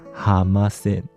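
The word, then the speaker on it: 浜線: 滨线是日本人的发音，叫做哈马线，哈马线音译过来就变成了哈玛星。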